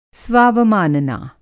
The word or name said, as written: स्वावमानना